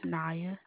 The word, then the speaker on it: Niya: It's Naya. – Naya. – Mm-hmm. – How would you spell that? – N-I-Y-A. – N-I-Y-A.